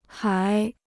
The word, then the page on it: 还: hái